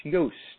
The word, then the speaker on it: Yoast: All right, and Helen is saying Juiced is pronounced Yoast, like the name of the plugin. You know what? I appreciate that because his company, we're talking about the WordPress SEO, his company is Yoast, Y-O-A-S-T. That's the name of his company.